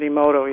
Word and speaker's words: Emoto: And folks, if you want to know anything about water memory, look at some of the research of Dr. Emoto. Is that correct? Did I pronounce his name right? – I believe that's how they pronounce it, Emoto, yeah.